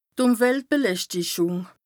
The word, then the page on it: d' Ùmweltbeläschtichùng: Bas Rhin